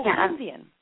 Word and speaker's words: Keynesian: I want to say Keynesian. Keynes? – Keynesian.